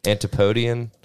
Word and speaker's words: Antipodean: Is that correct? Antipodean? – Yeah.